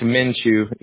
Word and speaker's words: Minshew: It's Minshew. Yeah. It's more like a C, but it's like a Ukraine thing, or whatever.